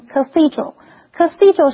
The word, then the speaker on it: cathedral: 这台车的仪表板设计得非常时尚。所以我们看的是 cathedral 这个名词，注意它的重音是在 THE 这个音节念成 cathedral。cathedral 是指教堂，例如 the 17th century cathedral was renovated last year。这座17世纪建的大教堂去年整修了。和 church, C-H-U-R-C-H 不太一样的是， cathedral 通常是指的大教堂或者是教区的总教堂。